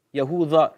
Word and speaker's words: Yahuda: Onun ismine Yahuda. Yahuda diye okunuyor. Arapçası Yahuda dal şeklinde. İbranice de de olarak okunuyor.